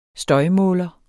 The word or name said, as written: støjmåler